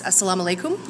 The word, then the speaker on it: assalamu alaikum: So I know that if I'm going to greet you, the proper greeting is assalamu alaikum.